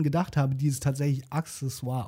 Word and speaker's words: Accessoire: Ich habe jetzt aber gelernt, es heißt Accessoire.